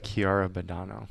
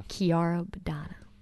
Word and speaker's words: Chiara Badano: How do you say this saint? – Blessed Chiara Badano. – Chiara Badano.